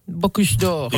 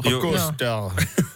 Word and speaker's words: Bocuse d'Or: Suomen Bocuse d'Or. Miten tää lausutaan? – Bocuse d'Or. – Bocuse d'Or.